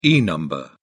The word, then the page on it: e number: British English